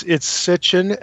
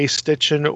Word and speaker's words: Sitchin: It's Sitchin, S-I-T-C-H-I-N, but it's easy to say Stitchin or spell it with an E at the end, or an I.